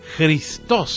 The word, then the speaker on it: Christos: El término Mesías En griego se dice Cristo. Christos sería la forma de pronunciarlo, y suponía muchas implicancias, ideas y expectativas, incluso opuestas, y que por lo tanto, según Jesús, había que purificar.